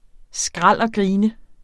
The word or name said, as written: skraldergrine